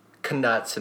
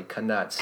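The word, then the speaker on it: Knuts: There's 29 canuts, and that's how you say it apparently, canuts.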